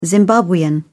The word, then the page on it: zimbabwean: British English